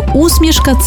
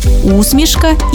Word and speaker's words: усмішка: Виявляється, що усмішка – це про невербальне транслювання чогось доброго, смішного. До речі, тут подвійний наголос. Усмішка і усмішка, як у Василя Симоненка.